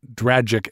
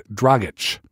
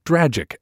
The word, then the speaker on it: Dragic: And I'm told that it's pronounced Dragic and not Dragic. Dragic rhymes with tragic or magic.